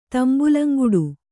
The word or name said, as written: ತಂಬುಲಂಗುಡು